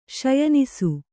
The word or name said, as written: ಶಯನಿಸು